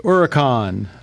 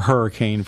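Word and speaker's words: Huracán: And the 2015 Lamborghini Huracan, or Hurricane for those that don't know how to pronounce it like me.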